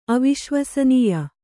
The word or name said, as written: ಅವಿಶ್ವಸನೀಯ